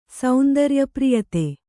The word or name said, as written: ಸೌಂದರ್ಯಪ್ರಿಯತೆ